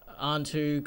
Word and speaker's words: Anhtu: Yeah, just follow me on Twitter at Antu Comedy. The handle is spelled A-N-H-T-U, the word comedy.